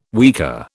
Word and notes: weka: British English

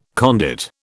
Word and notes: conduit: British English

